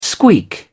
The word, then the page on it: squeak: British English